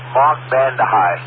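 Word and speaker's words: Mark Vande Hei: Mark Vandehei, that's how it's pronounced.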